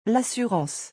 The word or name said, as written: l'assurance